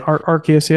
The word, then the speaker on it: Arceus: Like you said, because I don't know if you call it Arceus or Arceus, however people pronounce it. – Arceus, yeah.